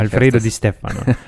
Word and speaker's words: Alfredo Di Stefano: Alfredo Di Stefano, wunderschön ausgesprochen.